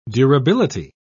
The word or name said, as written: durability